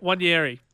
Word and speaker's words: Nwaneri: Wanieri. So Wanieri. – Yeah, Ethan Wanieri. He made his debut. – So N-W-A-N-E-R-I? – Yeah, so just basically forget the N. – Okay. – Wanieri.